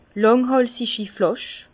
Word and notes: lànghàlsigi Flàsch: Bas-Rhin; Herrlisheim

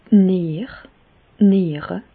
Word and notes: d' Nier, d' Niere (pl): Bas-Rhin; Reichshoffen

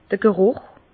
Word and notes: d'r Gerùch: Bas-Rhin; Herrlisheim

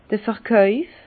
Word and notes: d'r Verkàuf: Alsatian; Bas-Rhin; Herrlisheim

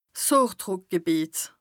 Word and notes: d' Hochdrùckgebìeter: Bas-Rhin; Strasbourg

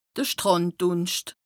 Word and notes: de Strànddùnscht: Bas-Rhin; Strasbourg

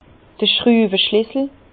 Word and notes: d'r Schtrüweschlissel: Bas-Rhin; Herrlisheim

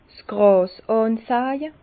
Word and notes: 's Gràs ànsàje: Bas Rhin; Herrlisheim

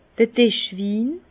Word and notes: d'r Dischwin: Bas-Rhin; Herrlisheim

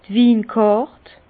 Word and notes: d' Winkàrt: Alsatian; Bas-Rhin; Herrlisheim

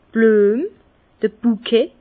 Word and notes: d' Blüem, d'r Buket: Bas-Rhin; Herrlisheim

